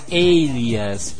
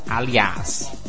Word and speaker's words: Alias: O nome da série se pronuncia Alias e não Aliás.